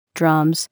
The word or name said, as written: Drums